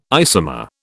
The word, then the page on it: isomer: British English